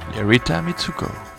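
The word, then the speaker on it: Rita Mitsouko: Rita Mitsuko.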